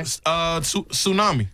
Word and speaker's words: tsunami: This has a silent letter in it. – Okay. – Tsunami. – Tsunami. – Tsunami. – Yeah. – Tsunami. – Sure does. – Now, some people might say tsunami. – Tsunami. – Tsunami. – Yeah. – Somebody might say that. No. They'd be wrong. – No, when black people first heard, they were saying, everybody like, you heard about that tsunami? – Yeah. – I can't. – These are English words with silent letters. This one kind of threw me when I first seen it because I'm seeing it in my head.